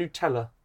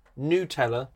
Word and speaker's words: Nutella: And Nutella. It's pronounced. Nutella, not Nutella.